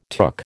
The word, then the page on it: took: American English